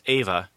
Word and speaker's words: Eva: Ava, spelled E-V-A, but pronounced Ava, and Christine, spelled C-H-R-I-S-T.